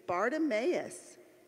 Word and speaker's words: Bartimaeus: Today, we've got a guy with four syllables in his name. His name is Bartimaeus.